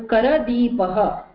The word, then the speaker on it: करदीपः: Kar Deepaha, if you tell, then it is Ra with Repha with a Halant. But here you have to pronounce that A. So Kara Deepaha, against Kar Deepaha.